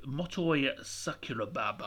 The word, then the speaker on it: Motoi Sakuraba: I'll go with Motoi Sakuraba. I butchered his name originally there.